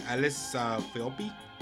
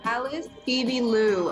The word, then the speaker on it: Alice Phoebe Lou: How do you say her name? Alice Philby? – Philby? It's Alice Phoebe Lou, I think.